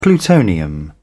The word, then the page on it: plutonium: British English